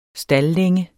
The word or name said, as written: staldlænge